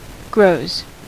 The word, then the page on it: grows: /ɡɹəʊz/; [ɡɹəʊ̯z]; /ɡɹoʊz/; [ɡɹoʊ̯z]